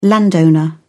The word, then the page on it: landowner: British English